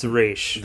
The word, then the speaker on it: Ra's: Ra's al Ghul, but they pronounced it Ra's al Ghul. It's Ra's. That's how you pronounce it.